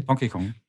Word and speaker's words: Donkey Kong: Weil der Rest, den du dir anschaust, ist alles Donkey Kong, neues Mario, Donkey Kong, habe ich es richtig gesagt? – Donkey Kong.